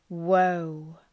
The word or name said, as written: woe